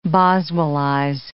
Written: Boswellize

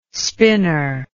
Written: spinor

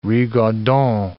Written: rigaudon

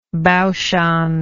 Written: baoshan

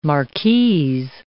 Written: marquise

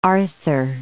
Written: Port Arthur

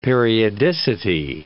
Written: periodicity

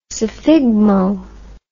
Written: sphygmo

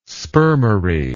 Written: spermary

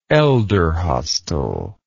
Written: elderhostel

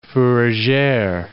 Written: fourragere

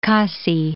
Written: Khasi Hills

